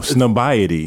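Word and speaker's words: Highsnobiety: Snobniety. – Like, no, Snobiety. – Yeah, Snobiety. – He said it right. – Did he say it right? The very first time.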